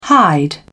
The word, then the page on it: hide: British English